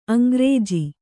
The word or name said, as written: ಅಂಗ್ರೇಜಿ